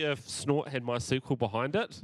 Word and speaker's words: MySQL: What about MySQL? How do you say that one? – MySQL. – Not my squeal? My squeal? – My squeal? No, my squeal? I don't, maybe if the logo was a pig.